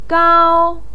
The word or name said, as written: gao1